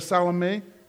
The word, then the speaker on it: Salome: The other gospels tell us that is Salome. That's how you pronounce that.